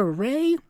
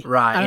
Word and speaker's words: Ray: Well, it's either Ray or Rai. – Rai, yeah. Apparently we say it wrong, but we may have been pronouncing it wrong this whole time, and we've been saying it for like 3 years.